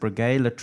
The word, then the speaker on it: Breguet: Breguet Tradition. Yes, that's right. Um, so this Breguet, um, it's really pricey when you buy it um, retail.